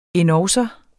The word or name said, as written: endogså